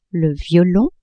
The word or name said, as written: violon